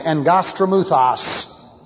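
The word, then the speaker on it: engos tramuthos (ἐγγαστρίμυθος): But the Greek, when this was translated into the Septuagint, the Greek is engos tramuthos. And engos tramuthos is a ventriloquist demon, spelled like this in the English, E-G-G-A-S-T-R-A-M-U-T-H-O-S. And the two G's together is pronounced like an N-G, engos tramuthos.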